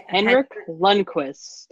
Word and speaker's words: Henrik Lundqvist: Henrik Lundqvist. – Lundqvist.